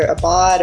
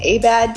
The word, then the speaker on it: Abad: Most people pronounce it either Abad or Abad. I kind of grew up pronouncing it Abad because my dad's white, but he's like at least part Filipino, and my mom's full Filipino.